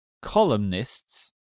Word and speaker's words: columnist: Do you say columnists or columnists? – Columnist. – Are you supposed to say the N? – Can confirm that you do pronounce the N. Fifth columnist.